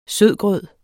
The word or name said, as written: sødgrød